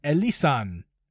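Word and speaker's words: Elysian: The Elysan, that's how you say it, Space Dust IPA.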